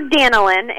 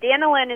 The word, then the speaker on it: Danilynn: And how did you get to D-A-N-I? – It is short. It's short for Danilyn, and Danilyn is spelled D-A-N-I-L-Y-N-N.